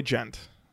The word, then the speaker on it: djent: It's gent. – Yeah. – Gent. – It's gent. Okay. – D-J-E-N-T.